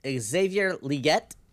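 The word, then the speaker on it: Xavier Legette: Wide receiver Xavier Leggett. That's how you say his name because that's what he said.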